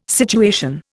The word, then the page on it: situation: Indian